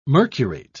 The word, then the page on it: mercurate: British English